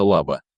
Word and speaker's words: Лаба: Кстати, в чешском варианте река Эльба тоже именуется Лаба, с ударением на первом слоге.